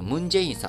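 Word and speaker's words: 文在寅: 例えば、あの、韓国大統領のムン・ジェインさん、えー、日本読みではそんな読み方しないわけです。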